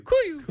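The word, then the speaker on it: KUIU: Kuyu.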